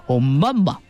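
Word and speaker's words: Oumuamua (ʻOumuamua): 天文学家呢给它命名叫做 Oumuamua 啊，这是夏威夷语的发音啊，在夏威夷语当中呢它的含义是远方的信使。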